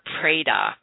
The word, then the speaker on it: Preidah: And it's called the Seder Preda, I think is how you pronounce the second word, P-R-E-I-D-A-H.